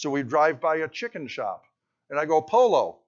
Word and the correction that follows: Pollo: He goes, no, it's Pollo. Then don't tell me to pronounce every letter.